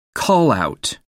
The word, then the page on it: call out: American English